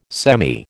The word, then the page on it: semi: American English